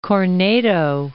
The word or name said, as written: Corneto